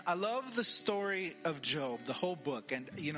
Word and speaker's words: Job: I know some people say his name Job. His name is Job.